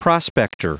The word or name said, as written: prospector